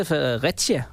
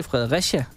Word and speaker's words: Fredericia: Han sagde Fredericia, og du siger Fredericia. Og så er der den tredje udtaleform, Fredericia.